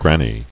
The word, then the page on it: Granny: gran·ny